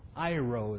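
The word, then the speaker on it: Iro (εἴρω): The root of the word is Iro. It's spelled E-I-R-O. Iro is how it's pronounced.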